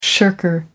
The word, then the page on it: shirker: American English